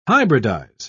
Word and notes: hybridise: British English

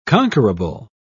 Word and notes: conquerable: British English

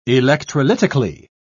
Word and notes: electrolytically: British English

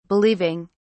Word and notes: believing: British English

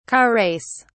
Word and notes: car race: British English

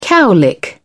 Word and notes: cowlick: British English

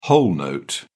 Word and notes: whole note: British English

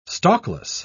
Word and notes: stalkless: British English

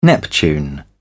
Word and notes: neptune: British English

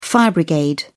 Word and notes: fire brigade: British English

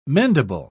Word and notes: mendable: British English

